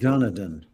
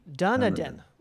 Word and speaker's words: Dunedin: I love Dunedin. – Dunedin.